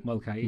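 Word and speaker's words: Moloka'i: You know, he pronounces it Molokai more correctly than we do. But yeah. – More correctly. – Well, okay. You know what I mean. You know. – Yeah, yeah. – Because I believe there's an apostrophe in there, and you always got to do that on the syllable, right?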